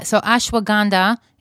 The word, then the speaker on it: ashwagandha: So you said it's ashwagandha. Is that how you pronounce it? – Yes. Ashwagandha. Right.